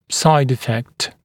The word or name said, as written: side effect